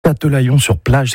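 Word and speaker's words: Châtelaillon-Plage: Châtelaillon sur plage, c'est comme ça qu'on prononce.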